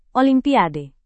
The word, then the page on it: olimpiade: Indonesian